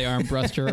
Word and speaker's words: Armbruster: It is Armbruster, right? – Yep, it's not Armbruster. – Okay, yep. Yeah. Yeah. All telemarketers always say Armbruster or Armbuster.